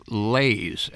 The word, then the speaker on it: lai: And these types of compositions are called lays, and that's a French word. It's spelled L-A-I.